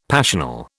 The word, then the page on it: passional: British English